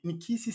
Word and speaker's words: Nkisi: Nikisse se escreve N-K-I-S-I, Nikisse.